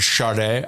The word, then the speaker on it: Sade: I want to know, why is it pronounced Sade when her name is spelled S-A-D-E? There's no S-H or R in Sade. Well, she's from Nigeria. Oh. So in Nigeria, they pronounce it Sade. I interviewed Sade and she said, my name is Sade.